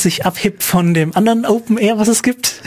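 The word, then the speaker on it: OpenAIRE: Das ist A-I-R-E. – Ja, aber das spricht man immer noch R aus. – Das spricht man immer R aus, okay. Aber gut, das heißt dann Open Air.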